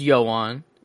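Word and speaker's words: Ioan: It is Welsh. I'm checking multiple sources. It is Yoan. – Okay. – Griffith. – Griffith. – The second source says Griffith.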